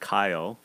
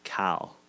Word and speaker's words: Kyle: It's not Kyle with two syllables. It's Cal. Cal. Cal Lowry.